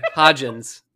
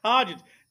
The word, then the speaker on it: Hodgins: Isaiah, yeah. Hodgkins. – There's no K though. – There's no K. Hodgins. – Hodgins.